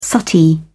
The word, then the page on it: suttee: British English